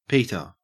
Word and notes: peter: Australian English

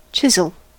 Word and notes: chisel: American English

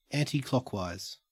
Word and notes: anticlockwise: Australian English

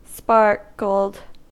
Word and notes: sparkled: American English

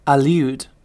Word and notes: allude: British English

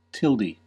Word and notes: tilde: American English

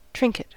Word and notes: trinket: American English

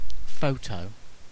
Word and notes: photo: British English

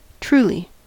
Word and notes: truly: American English

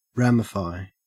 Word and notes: ramify: Australian English

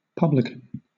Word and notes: publican: British English

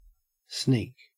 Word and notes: sneak: Australian English

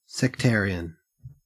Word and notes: sectarian: Australian English

